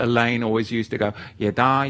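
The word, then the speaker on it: Yada (ידע): Da'at, it's spelt Dalet Ayin Tav, and it is a covenant term, whereas the other Hebrew word to know is Yada. It is Yod Dalet Ayin. Yada.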